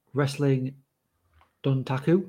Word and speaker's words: Wrestling Dontaku: Osprey versus Shingo from Wrestling Dontaku.